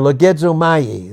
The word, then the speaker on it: logizomai: The Greek word is logizomai. Logizomai. Or logizomai is the proper pronunciation of that Greek word.